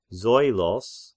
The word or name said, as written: Zoilos